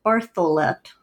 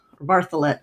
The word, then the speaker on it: Bartholet: I'm going to pronounce it Bartholet, Bartholet, I'm going to stick with that. I'm going to spell it for listeners. Elizabeth, B-A-R-T-H-O-L-E-T.